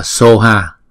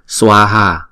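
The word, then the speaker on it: svāhā: Có khi á, các bạn thấy là chữ này người ta đọc là soha. Soha là từ phiên âm của tiếng Tây Tạng. Tiếng Phạn là chúng ta đọc là swaha, swa, swaha.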